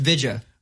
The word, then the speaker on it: Vijay: It's spelled V-I-J-A-Y. – Yeah. – And I was like, that shit says VJ. – That's VJ. – Yeah. No, it's Vija.